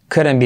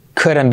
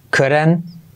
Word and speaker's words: couldn't: Couldn't, no sería couldn't, sino couldn't, que lo curen, que lo curen, couldn't.